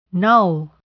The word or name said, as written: null